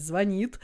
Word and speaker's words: звонит: все исправляли, я выучила, что правильно говорить «звонит».